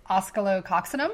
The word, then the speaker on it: Oscillococcinum: So, um, let's see if I pronounce this correctly. So, I call it Oscillo, but it's Oscillococcinum. Is that correct? Yep, I think. – I think yeah.